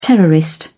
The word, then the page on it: terrorist: British English